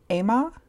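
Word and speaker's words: Ema: Number 9 is Emma, is how you pronounce it, which is a film by Pablo Larraín, who also directed Spencer, which came out this year.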